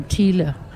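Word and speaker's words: Thile: Years ago when I pronounced his last name, Thiele. And he said, oh, finally someone who can pronounce it the right way.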